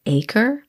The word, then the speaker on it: Aker: It's, I think it's pronounced acre. I looked at it. It's spelled A-K-E-R.